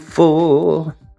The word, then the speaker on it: for: When she gets there, she knows if the stores are all closed, with a word she can get what she came for. Non si pronuncia mai l'R alla fine. Quando l'R è l'ultima lettera, non è mai pronunciato F-O-R. For.